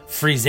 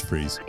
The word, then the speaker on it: Frise: And it is pronounced Frise. – I'm pretty sure it's Freeze.